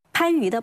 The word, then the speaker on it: 番禺: 那么类似的例子，比如广东有一个地名叫番禺，它的这个番字在正常情况下应该读番，可是就在这个地名里面保留了远古古无清纯音时代的发音，它读番。